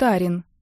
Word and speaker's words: Арин: Кажется, его зовут Арин, с ударением на первый слог.